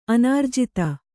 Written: ಅನಿರ್ಜಿತ

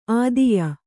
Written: ಆದೇಯ

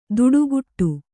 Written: ದುಡುಗುಟ್ಟು

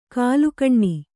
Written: ಘಲಕನೆ

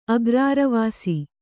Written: ಅಗ್ರಾರವಾಸಿ

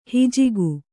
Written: ಹಿಜಿಗು

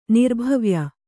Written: ನಿರ್ಭವ್ಯ

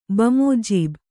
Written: ಬಮೋಜೀಬ್

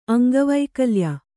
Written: ಅಂಗವೈಕಲ್ಯ

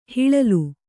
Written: ಹಿಲ್ಲೋಲ

